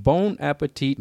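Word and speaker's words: Bon Appétit: The first topic is from Bon Appetit magazine. – That one was fine.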